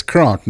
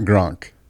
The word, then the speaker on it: Kronk: Pronounced Kronk, by the way. – It's Kronk, not Gronk.